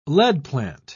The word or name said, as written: leadplant